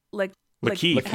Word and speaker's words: Lakeith: Lakeith. I say Lakeith because it says it's Keith with Law in front of it. – Yeah. – Lakeith. – Okay, Lakeith.